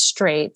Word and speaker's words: Strait: And because my last name is Strait, it's spelled like the country singer George Strait.